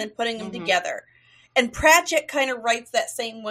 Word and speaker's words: Pratchett: Pratchett.